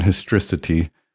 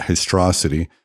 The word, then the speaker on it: historicity: Thank you to someone who said I was butchering that word historicity and saying it histrosity. I've heard it 1,000 times, but for some reason I got stuck on saying it the wrong way.